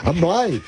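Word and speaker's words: Ann Arbor: Anne Blythe. Si eres de Michigan, Anne Blythe.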